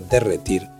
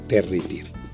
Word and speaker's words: derretir: Siguiendo este fenómeno, todo quisque pronuncia todo quisqui, o muchos articulan menisco, vesícula o derretir como minisco, visícula y derritir, incluso dirritir. Semejante como semejanta, semajante o pupurri como pupurri, tras el paso intermedio de popurri.